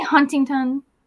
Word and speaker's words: Huntingdon: Well, we say Huntingdon. Done. Okay. I try to emphasize the D, but you can say it with a T, but you got to spell it with a D.